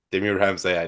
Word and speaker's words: Demir Hamza: Demir Hamza in English.